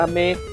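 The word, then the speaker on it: Aimé: Reggie Fields AIM, en el cual pues... Se pronuncia AME. – es AME. – Ame.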